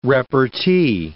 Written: repartee

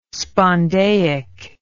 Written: spondaic

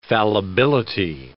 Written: fallibility

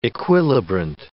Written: equilibrant

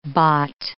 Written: bott

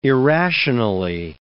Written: irrationally

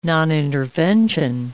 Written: nonintervention

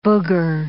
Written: booger

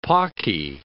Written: pawky

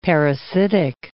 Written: parasitic

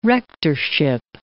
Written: rectorship

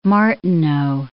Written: Martineau

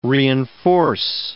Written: reinforce

